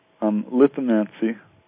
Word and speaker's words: lithomancy: Why would you prefer lithomancy? Is that right? Am I pronouncing that correct? – Lithomancy, correct.